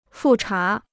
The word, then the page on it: 复查: fù chá